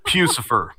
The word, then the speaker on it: Puscifer: P-U-S-C-I-F-E-R. – Almost like, I think it's Lucifer, but it's Pussifer. – He's running. – It's Pussifer.